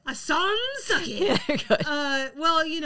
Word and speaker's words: Sans Souci: La Place Sans Souci. – Souci. Mm-hmm. Okay. So, more specifically, I am referencing the Sans Souci part.